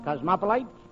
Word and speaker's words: cosmopolite: Typical cosmopolite. Happens to be the correct pronunciation.